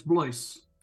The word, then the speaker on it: Blois: Close enough, it's Blois.